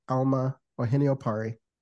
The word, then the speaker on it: Alma Ohene-Opare: So in Ghana, where I'm originally from, my name will be pronounced Alma Ohini Opari.